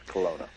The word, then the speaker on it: Kelowna: In Canada, the first annual Harold Danielson Memorial Open took place at Breakers Billiards in, am I pronouncing this right, Kelowna, British Columbia? – Yes, Kelowna.